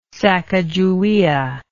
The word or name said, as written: sakajawea